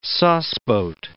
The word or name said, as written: sauceboat